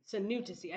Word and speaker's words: Sinouthasy: Did I say that correctly? – Sanutasi.